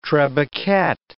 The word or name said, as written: trebucket